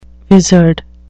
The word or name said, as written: vizard